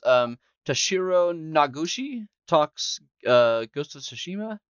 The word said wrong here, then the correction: Toshihiro Nagoshi: How bad did I butcher that? – Toshihiro Nagoshi. – Nagoshi. Okay.